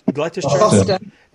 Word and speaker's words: Gloucester: and you'll have to pronounce this for me, Gloucester. Is that right? Gloucester? Gloucester. Thank you.